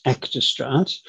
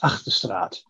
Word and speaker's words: Achterstraat: Um, the most common one is actostrat. When I grew up, uh, couldn't speak English uh, at the age of four, and we pronounced it as achterstraat. But when we, when I learned English at four years of age, it sort of changed to actorstraat.